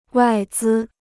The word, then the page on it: 外资: wài zī